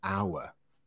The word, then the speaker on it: hour: It's the sound. So it sounds like it's a vowel sound. For that reason, we use an, an hour.